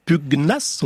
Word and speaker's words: pugnace: Pugnace, on prononce bien le G.